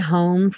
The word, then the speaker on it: home: I'll be home for Christmas. Já vimos que esse I will, quando contraído, soa como I'll. I'll be home for Christmas. E o home, sem pronunciar o E, nada de home.